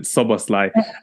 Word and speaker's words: Szoboszlai: They're going to be facing off against Alexis McAllister, Dominic Szoboszlai, as you pronounce it, Szoboszlai, as well, and potentially Curtis Jones in that midfield as well.